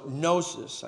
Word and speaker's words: gnosis: So gnosis, I think I just spelled that wrong, G-N-O-S-I-S. That's where we get our word knowledge from. Just like the K is silent at the beginning of knowledge, so the G is silent at the beginning of gnosis or Gnosticism.